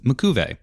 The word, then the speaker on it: Makuve: But in 2000, the translators working on the dub decided to go with Makuve, with the emphasis on the ku part of the name.